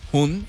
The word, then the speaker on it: Hun: Si es que la pronunciamos Hun. Bien. Hun. – Ajá, la H-U-N. – Banda se llama de H-U-N.